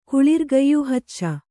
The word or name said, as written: ಕುಳಿರ್ಗಯ್ಯುಹಚ್ಚ